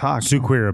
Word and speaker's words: Suiker: S-U-I-K-E-R. – S-U-I-K-E-R. – apparel. Suquir. Suquir apparel. – Yeah, that's exactly how you pronounce it. Yeah, that's how they say it in South Africa.